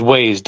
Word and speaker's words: Waiz: It's Shesta Weiss. It's Shaesta is her first name. Last name is Waiz, Waiz. So I want to give her, hope I didn't butcher her name too much.